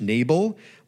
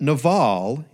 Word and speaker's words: Nabal: You might want to pronounce it, as it is spelled in the English Nabal, but Naval is closer to the Hebrew pronunciation.